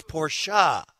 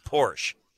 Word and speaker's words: Porsche: Jim wanted to make sure that we all knew that it's Porsche, not Porsche. – Yeah, two syllables.